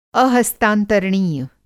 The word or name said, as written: अहस्तांतरणीय